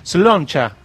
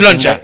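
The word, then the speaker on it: Sláinte: Slainte. – Sloncha, se pronuncia en irlandés. – Sloncha.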